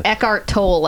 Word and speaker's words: Eckhart Tolle: Eckhart Tolle, I think is how you pronounce it.